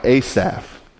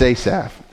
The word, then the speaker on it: Asaph: but Psalm 73 was written by Asaph. That's how I'm going to pronounce his name. I think there's different pronunciations, but it's Asaph. And so he wrote 12 of the Psalms.